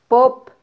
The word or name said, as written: pop